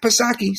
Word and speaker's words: Psaki: But Jen Psaki, silent P, of course, she says that Kamala is not visiting the border right now because she's doing exactly what the president asked her to do.